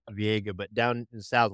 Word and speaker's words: Viega: Anyway, you look at it in the English context, it looks like it would be pronounced Viega.